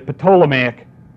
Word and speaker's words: Ptolemaic: It is the, if you pronounce it the way it's written, it'd be the Ptolemaic dynasty, and you can pronounce it different, Ptolemaic dynasty or Ptolemaic dynasty.